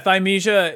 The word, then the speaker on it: Thymesia: Yeah, Thymesia.